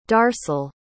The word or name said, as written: darcel